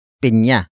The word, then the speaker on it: pinya: It is the focus marker that introduces the main topic of the sentence, and that is pinya. Pinya. Pinya. Pinya is spelled as P I N Y A.